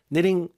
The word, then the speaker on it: knitting: 우리 그 뜨개질을 knitting이라고 하잖아요. – 그렇죠. – 물론 K는 묵음이지만. Knitting, 뭐 뜨개질을 하면 이렇게 촘촘히 짜야 되잖아요.